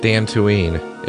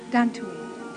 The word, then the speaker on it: Dantooine: Dantooine. It rhymes with Tatooine. – Right. – Dantooine.